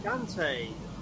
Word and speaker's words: Shantae: Okay, how's Shantae? Is that how you say it? – Shantae, half genie hero.